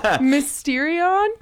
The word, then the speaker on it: mysterion: How do you say it? – After you. – Mysterion. – Mysterion. Good job.